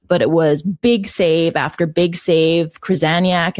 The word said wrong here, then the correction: Krzyzaniak: am I saying her name right? – Krzyzaniak, yeah.